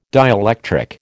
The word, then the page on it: dielectric: American English